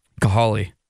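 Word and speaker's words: Kahale: Cahale.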